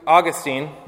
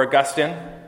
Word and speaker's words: Augustine: According to Augustine, or Augustine, again, you can pronounce his name a couple different ways, before the fall of Adam and Eve, we were able to sin and able not to sin.